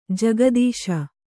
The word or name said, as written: ಜಿಘತ್ಸು